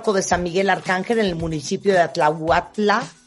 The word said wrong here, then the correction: Atlautla: Atlautla, Atlautla. – Atlautla. – Atlautla, estoy muy mal con mi pronunciación.